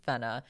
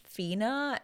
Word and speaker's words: Fena: I have confirmed, by the way, it is pronounced Fena. Uh, I thought it was going to be pronounced Fina, and so I was saying Fina for a long time, even though in the, the sub they actually do say Fena.